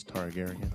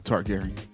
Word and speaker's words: Targaryen: Yeah, how do you say it? – Queen Daenerys Targaryen. – Okay. Targaryen. Yeah, so that all have something to do with some shit.